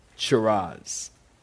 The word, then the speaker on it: Charaz: In the Hebrew language, there is a word, Charaz. Charaz. I'll spell it for you. C-H-A-R-A-Z.